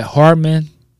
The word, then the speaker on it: Hardman: She said Hardman.